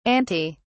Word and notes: antae: British English

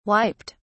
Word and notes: wiped: British English

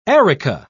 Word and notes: areca: British English

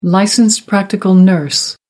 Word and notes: licensed practical nurse: American English

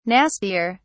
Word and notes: nastier: British English

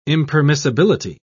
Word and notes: impermissibility: British English

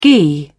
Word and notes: ghee: British English

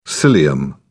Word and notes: cilium: British English

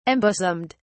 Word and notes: embosomed: British English

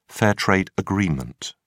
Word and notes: fair-trade agreement: British English

